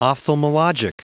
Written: ophthalmologic

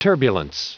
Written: turbulence

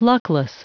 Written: luckless

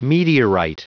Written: meteorite

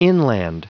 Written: inland

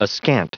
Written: askant

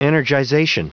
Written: energization

